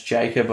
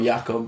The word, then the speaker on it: Jakob: And like, I don't know if it's Jacob or Jakob, but I like Poodles. – Jakob. – Silent J.